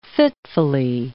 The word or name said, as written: fitfully